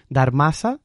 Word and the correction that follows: Dharamsala: se llama. Dharamsala.